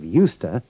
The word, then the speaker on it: used to: Listen carefully to the pronunciation of used to in these sentences and dialogues. The E and the D of used aren't pronounced, and the O of two is a schwa.